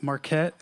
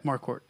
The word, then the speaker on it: Marquardt: It's not Marquette, and it's not Marquardt. – Marquardt. Yeah, like a quart of water.